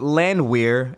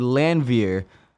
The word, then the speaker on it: Landwehr: But my partner told me that it wasn't Landwehr, it's actually Landwehr. Yes. I've been saying it wrong all week. – Yeah, in German, W's are pronounced with a V.